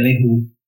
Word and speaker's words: Leroux: Ah, que é inspirado no livro do Gaston Leroux, L-E-R-O-X. É, Leroux. Uhum.